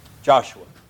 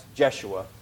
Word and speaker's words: Joshua: Joshua, which is sometimes pronounced Jeshua, or as you would have it in the Greek, Jesus.